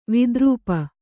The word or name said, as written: ವಿದ್ರೂಪ